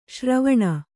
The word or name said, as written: ಶರವಣ